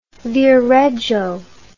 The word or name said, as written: Viareggio